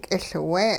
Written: k'iLwe

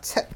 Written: tsiq'

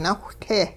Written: nawhte

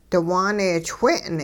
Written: diwaaneechwe'n

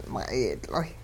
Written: ma'iyidloy'

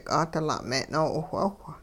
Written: 'aadila' me'-noo'iwh'awh

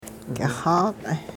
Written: k'ixaq'

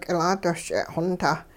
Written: k'ilaadosch'e' xontah

